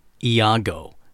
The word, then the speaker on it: Iago: I was looking it up on the internet, and I did find out that the British pronunciation, which this is Shakespeare we're talking about, is Iago. So there you go, Iago.